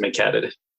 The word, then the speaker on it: McHaddad: My family always pronounces it McHaddad.